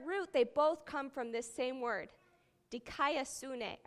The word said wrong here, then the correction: dikaiosune: I butchered that. It's been a long time. Dikaiosune.